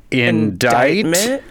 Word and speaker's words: indictment: This word has a silent C, and it was looked up a lot because of four separate legal cases. – Is the word... Indictment? – Or yes!